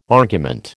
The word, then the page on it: argument: American English